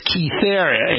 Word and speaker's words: Kythera: The name of the company, people have been asking me this, is Kythera. It's spelled K-Y-T-H-E-R-A.